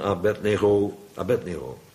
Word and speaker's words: Abednego: Daniël 3, waar letterlijk zo'n beeld staat in Babel en waar Sadrach, Mesach en Abednego, ja, anders komt het met de muziek niet overeen, dus zo moet je het uitspreken, Sadrach, Mesach, Abednego.